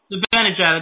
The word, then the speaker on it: Zibanejad: Zibanejad, that's right.